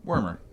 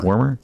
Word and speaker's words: Wormer: Wormer? – I think so. Wormser? – Wormer? – Wormer. – Wormer? It's Wormer? – Yeah, got him right.